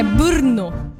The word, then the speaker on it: Brno: Então, eu vou me esforçar para falar bem corretamente. É Brno.